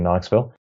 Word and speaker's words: Knoxville: It's at Functional Knox, just like it sounds, K-N-O-X. There is a silent K in Knoxville.